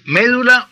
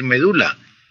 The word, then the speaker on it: médula: En español existen palabras que tienen una doble pronunciación, como las que usted cita. Se puede decir médula o se puede decir medula. Se puede decir alvéolo o se puede decir alveolo, el lugar donde se alojan los dientes.